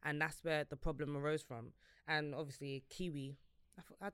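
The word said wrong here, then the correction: Kiwior: I don't know how. So is it a V? – I think the Polish pronunciation is Kivior. Yes. Yeah.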